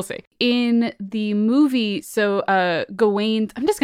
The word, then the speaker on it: Gawain: For the record, the movie pronounces the main character's name as Garwin. I've always said Gawain, and I'm going to probably say Gawain for this episode, just because that's what I know of.